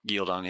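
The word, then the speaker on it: Ghealdan: So Loghain is the cold open for episode 4, where we see him in Gyeldon. I hope I'm saying that right. Am I saying that right? – Yeah, you got it. Gyeldon, yeah.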